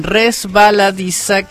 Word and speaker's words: resbaladiza: Lo digo bien, resbaladiza, que se escuche la S.